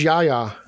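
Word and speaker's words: Yahya: Um, I believe it's pronounced Yaya.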